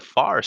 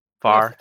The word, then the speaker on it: Fahr: His almost unlimited mana allows him to detonate these little orbs called Far Stones, or at least that's how I think you pronounce it, right? Far, F-A-H-R. – Far.